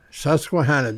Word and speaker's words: Susquehanna: How do you say that? Susquehanna River? – Susquehanna.